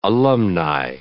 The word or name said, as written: alumni